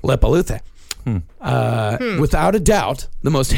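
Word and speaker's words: Leppalúði: These I know I'm actually pronouncing right. – Okay. – There is a Grilla and Lepaluthe. – Huh. – Yeah. Grilla and Lepaluthe.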